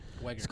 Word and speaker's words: Zellweger: Renee Zellweger in Judy. Yeah, that's how you pronounce it. Wegger.